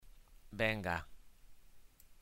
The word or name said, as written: venga